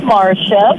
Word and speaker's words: Marcia: My name is Marcia, and it is spelled M-A-R-C-I-A.